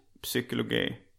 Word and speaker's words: psykologi: det är alltid ett stumt p när man börjar med ja, jag tror det. – psykologi.